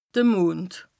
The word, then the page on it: de Mond: Bas-Rhin; Strasbourg